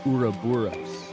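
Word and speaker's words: ouroboros: It's like a rubberous is the snake that's eating its tail. – It's, but partly because I've always pronounced it uroboros.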